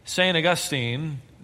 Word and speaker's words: St. Augustine: The city of St. Augustine, that's how it's pronounced, I was told, not St. Augustine, St. Augustine, emphasis on the ain, St. Augustine, I think is how she said it.